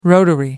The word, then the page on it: rotary: American English